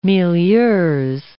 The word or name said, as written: milieux